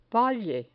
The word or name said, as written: valle